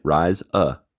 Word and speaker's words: Ryza: And I'd said that one right. Ryza.